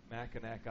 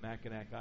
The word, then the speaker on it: Mackinac: just in Michigan suffering at Mackinac Island at the Grand Resort,